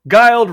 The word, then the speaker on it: Guild: The publicity team declared, quote, "Guild rhymes with wild," end quote.